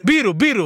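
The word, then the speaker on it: Birro: Peter Byrå, visst? Byrå va? Så uttalar man det.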